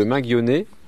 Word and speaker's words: Mainguionnet: Donc les 19 et 20 septembre, donc nous sommes à Gourin, au manoir de Mainguionnet. On le prononce comme ça, ou alors à la Bretonne.